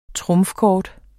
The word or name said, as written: trumfkort